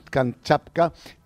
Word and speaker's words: Kamchatka: Para poder pronunciarlo bien, Kamchatka.